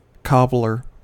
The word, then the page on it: cobbler: American English